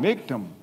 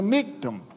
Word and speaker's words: Michtam: Now, I'm not sure I pronounced that exactly right, but a Michtam, and Pastor Michael said, well, because you're the, from the South, you kind of sound mictum. It's a Michtam.